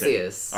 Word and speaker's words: Arceus: How do you pronounce Arceus.